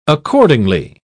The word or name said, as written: accordingly